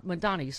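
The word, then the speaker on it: Mamdani: There's got to be, like, progressive affordability candidates like Mayor Mondami. – Yeah. – Mondani, sorry.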